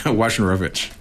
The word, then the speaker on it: Wojnarowicz: And you start out with and I'm totally going to mispronounce his name, the David Wojnarowicz. – Wojnarowicz.